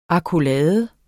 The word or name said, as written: AKKOLADES